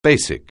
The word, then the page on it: bashful: anglais